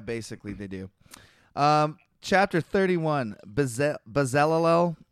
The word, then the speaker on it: Bezalel: I'm just going to call him Bezalel. Oh, God. – Spell it. – B-E-Z-A-L-E-L. L-E-L. Bezalel.